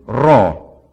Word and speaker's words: rho: It looks like the English P in the lowercase, but it's pronounced rr. If you can't roll your R, don't worry about it. You just go rr, like you're growling. It's raw, as in rr, raw.